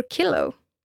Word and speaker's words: kilo: In Swedish, you can pronounce the K in kilo, or kilo in English, as either as the K in the word keep, or as the sh in the word church.